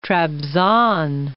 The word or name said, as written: Trabzon